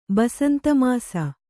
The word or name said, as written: ಬಸಂತಮಾಸ